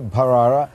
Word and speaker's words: Bharara: And for all my viewers, you're getting the correct pronunciation here, Bharara, aspirated H.